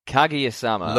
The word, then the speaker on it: Kaguya-Sama: Kaguya-Sama. – Love is War. Brilliant. Okay, tell me about Kaguya-Sama. – Yes, okay, this week we're watching Kaguya-Sama Love is War